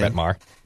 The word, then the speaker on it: Brett Maher: Is it Brett Maher? Is that how we pronounce the Dallas kicker's name? – Brett Maher.